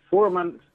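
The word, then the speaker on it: Fürmann: And Benno Fehrman, did I pronounce his name correctly? – Yes, Fuhrman. – Yes.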